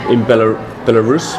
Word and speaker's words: Belarus: But as a company, it's quite impressive, because they're based in Belarus.